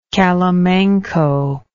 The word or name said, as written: calimanco